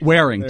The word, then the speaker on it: Waring: And is it Waring or Waring? – Waring. Waring. That on the pronunciation, it sounds like Waring.